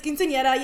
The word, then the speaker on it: quinceañera: And I know how to say it. It's quinceañera. Yes, I understand. I said it with an accent.